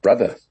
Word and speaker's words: [Br]Other: Yeah, it's just brother. That's how you pronounce it.